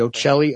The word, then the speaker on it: Ochelli: Well, I'm just right now I'm just using the Facebook page for the Ocelli Effect. It's spelled O-C-H-E-L-L-I, and that is my last name.